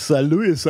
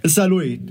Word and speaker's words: Saarlouis: Also nicht Saarlouis, aber. Sondern Saarlouis. Mit einem stummen S quasi.